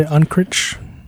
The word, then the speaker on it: Unkrich: Lee, how do you say his name? Unkrich? Is that how you said his name? – Yeah, I would say Unkrich.